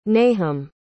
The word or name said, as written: nahum